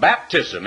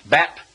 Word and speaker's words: Baptist: You can't find any place in the entire Bible where, because the one rite of baptism is required of Christians, that the church ought to be called the Baptis, as they call it in this part of the world, and spell it B-A-B-T-I-S. I get a lot of letters where people write it that way when they write in and say, I used to be a Baptist. Well, that's what they've always heard it pronounced.